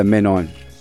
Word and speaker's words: Menon: Ah, man, ladies and gentlemen, we're with you guys with M-E-N-O-N right here. I like the line about the Menon. – Say it right. It's Menon, not Menon.